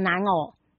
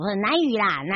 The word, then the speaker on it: 南屿: 对，这个蓝屿路线哦，很特别哦，啊，不是那个蓝屿哦，南东南西北的南，那个南屿，那个屿哦，就一个耳字旁啦，然后偶遇的偶，它，但是很多人会把它念成南偶。好，南屿啦，南屿啦，哈，请听清楚哈，啊，所以呢，请大家这个南屿路线呢，很多人都说这个是个宝藏的地方哦，而且这一个是一个挖宝的地方，为什么这么说啊？我们是不是请教一下我们的科长？